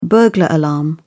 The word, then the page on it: burglar alarm: British English